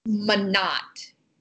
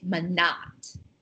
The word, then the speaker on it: Monat: Manat. – Mm-hmm. M-O-N-A-T. – Like M-O-N-A-T. Manat. – Yeah, I think that's how you say it.